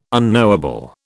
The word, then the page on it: unknowable: British English